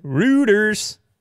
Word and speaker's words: Reuters: It's Reuters, right? – Reuters. – Routers? – Routers, that's what you said the one time.